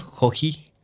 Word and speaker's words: Joji: Eh, no sé cómo se pronuncia, me imagino que es Joe Jai. – es Joji. Joji.